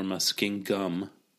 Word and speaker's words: Muskingum: Big debate online this week whether it's pronounced Muskingum or Muskingum. Muskingum. Spoiler alert.